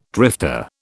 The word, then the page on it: drifter: British English